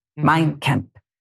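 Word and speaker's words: Mein Kampf: This means my struggle, me and Kemp. Did I say it right, Senator? – Mein Kemp.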